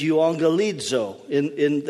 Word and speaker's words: euangelizo: Evangelism is euangelizo. That's how it would be pronounced in Greek.